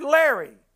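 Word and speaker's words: Laoghaire: it's pronounced almost like we would say the word Larry. It's Laori.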